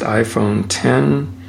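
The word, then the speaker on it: iPhone X: Roman numeral X, pronounced iPhone 10, with facial recognition called Face ID, with an infrared camera.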